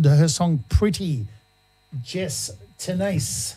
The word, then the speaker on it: TENACE: Tennis. Spelled T-E-N-A-C-E. How would you pronounce that? – I can't. Tennis. – Tennis, there we tenace. – Go. Just tennis, not tennis.